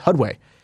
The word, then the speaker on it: Hudway: I think you say it's pronounced Hudway. It's Hudway Glass, like a HUD, like a heads-up display, Hudway Glass.